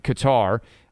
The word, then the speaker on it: Qatar: Out in Qatar, which I believe is the way you're supposed to pronounce it.